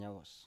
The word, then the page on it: yawos: Biak